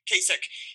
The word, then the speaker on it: Kasich: Kasich? Yeah. – Kasich, he's currently polling at 2% after the debate.